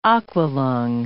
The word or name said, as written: Aqua-Lung